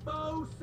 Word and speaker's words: faux: These are genuine faux sapphire earrings. 14 karat gold, 86 karat faux sapphire. Faux is a French word. It's got an X in it, but you don't even pronounce the X.